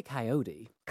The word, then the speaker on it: coyote: Do you say coyote? – We say coyote. – Coyote, Yeah. – even though it's spelt with an O.